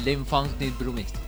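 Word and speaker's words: L'Enfant des Brumes: Entonces se pronuncia Le Enfant des Brumes. – Gracias, usted sí aprendió francés formalmente. – Porque como lo dijiste suena como el elefante y la bruma.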